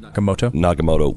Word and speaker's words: Nakamoto: Nakamoto.